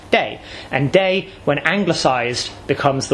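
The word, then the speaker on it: day (茶): In Minanhua, the pronunciation of this character that means tea is day.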